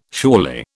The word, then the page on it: surely: British English